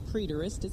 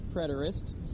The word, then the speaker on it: preterist: The idealist school, the preterist, and by the way, I pronounced it wrong all last week. It is not preterist, it's preterist.